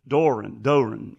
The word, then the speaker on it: Doerun: Doran. Anyway, it's spelled Doerun.